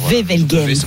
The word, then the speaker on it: Wevelgem: Wevelgem, voilà.